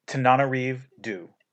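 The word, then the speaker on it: Tananarive Due: And it is, you do it for me. – Tanana Reeve Dew. – We were mispronouncing her name for several episodes.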